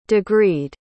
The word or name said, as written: degreed